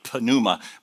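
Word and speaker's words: pneuma (πνεῦμα): And pneuma in English, if you were to spell it, the Greek word, it would be P-N-E-U-M-A, like pneuma, but the P is silent. So pneuma.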